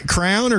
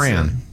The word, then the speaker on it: crayon: Do you say crown or crayon or how do you pronounce it? – Crayon. – Crayon? – Crayon. – Yeah, see? – Yeah, you say that. – Yeah. Because I do say it because that's how it's pronounced.